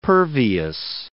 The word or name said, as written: pervious